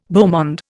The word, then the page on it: beau monde: Indian